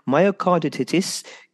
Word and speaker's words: myocarditis: This week I interview Andy Jansons, who is the founder of Alexander Jansons Myocarditis UK charity.